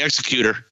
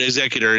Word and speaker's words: Executor: Yeah, it's the Executor. – Yeah. Okay, Executor. – Or the I think it's the Executor. – It's probably closer to Executor. It's just a different. Yeah, Executor. – A different emphasis on the different syllable.